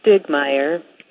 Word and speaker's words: Stiegemeyer: And you spell my last name S-T-I-E-G-E-M-E-Y-E-R. We pronounce it Stigmeyer, which is an Americanized version of this German name. Probably the German would be Stiegemeier.